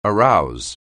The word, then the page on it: arouse: anglais